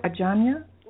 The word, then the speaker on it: Ajanya: Did I say that right? Ajanya? – You did. Perfect. Rhymes with lasagna.